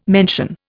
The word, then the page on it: mention: Indian